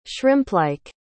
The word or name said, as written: shrimplike